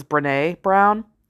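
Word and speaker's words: Brené Brown: It's Brene, which I thought was like Bren Brown, but there's like an asterisk, an accent kind of symbol over the E, so somebody else said it was Brene Brown. And that's a reader for you, where you pronounce something wrong because you're reading it and you haven't heard it.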